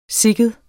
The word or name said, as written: sikken